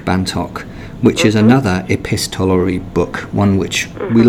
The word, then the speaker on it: epistolary: So the Potato Peel Pie book is an epistolary novel. I hope I've said that correctly. – You have.